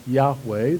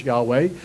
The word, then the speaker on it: Yahweh: We think it's pronounced Yahweh. There are no vowels in the original, but we think it's Yahweh.